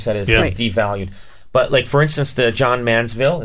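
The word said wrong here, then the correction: Johns Manville: is that, did I say that right? – John's Manville? – John's Manville. So that,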